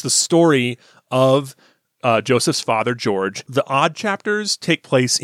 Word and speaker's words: Jorge: George Joestar, if you're looking for it on the internet, if you're looking for it on the internet, it's spelled like Jorge Joestar, J-O-R-G-E Joestar. Although the protagonist, George Joestar, explains it to you at the very beginning. He explains that it's pronounced George.